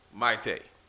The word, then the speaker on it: Maite: And I wanted to shout out Victoria Monet, Doja Cat, and Maite. Not Maite. – I thought it was Maite. Maite. Maite is Prince's ex-wife. Maite.